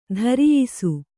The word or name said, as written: ಧರಿಯಿಸು